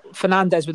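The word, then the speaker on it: Fernandes: Well, he's Portuguese, isn't he? So. – Is that how you say it? – Yeah, they say S is like shh, like their gold member. – Well, Fernandez with a funny S scored an absolute crack.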